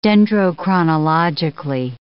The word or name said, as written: dendrochronologically